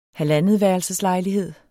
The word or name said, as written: halvandetværelseslejlighed